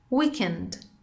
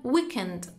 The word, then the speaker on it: weekend: Weekend weekend آخر هفته. توی امریکن weekend تلفظ میشه، استرس روی سیلاب اول هست، و توی بریتیش weekend weekend استرس روی سیلاب آخر.